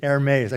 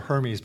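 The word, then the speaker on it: Hermès: I know that a Versace, or a, I recently found out the correct pronunciation, Hermes. I called it Hermes before.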